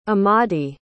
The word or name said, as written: amadi